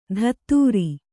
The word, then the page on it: ಧತ್ತೂರಿ: Kannada